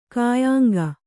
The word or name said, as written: ಕಾಯಾಂಗ